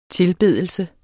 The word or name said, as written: tilbedelse